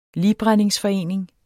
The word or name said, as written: ligbrændingsforening